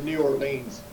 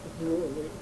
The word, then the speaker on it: New Orleans: About, how do you say that? – I said New Orleans. – New Orleans, okay.